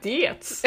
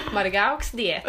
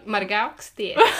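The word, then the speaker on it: Margaux Dietz: Är det så man uttalar det? – Ja, jag har sagt diets. – Margaux diet.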